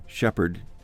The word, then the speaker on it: Shepherd: And by the way, Shepherd, for you Brits, is spelled King James Version, Andy. So S-H-E-P-H-E-R-D.